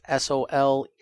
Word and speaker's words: Soleil: And some people, it's a little bit of a different word, French for sun. So I'm going to spell it real quick. It's just Soleil.